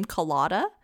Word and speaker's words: Kolyada: As we said in a previous episode, they pronounce his name Kolyada.